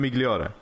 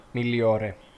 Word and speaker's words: Migliore: Var det Migliore? Migliore. Migliore. – Det där G, ja, någon G dag kommer det sitta. uttalas, uttalas Gli. Gagliardini.